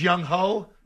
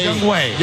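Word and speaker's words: Younghoe: They still think it's Young Ho? – No, that's Young Wei. – Young Wei Koo.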